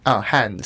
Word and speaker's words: Han: And it's pronounced Han? – Han, so okay. H-A-N.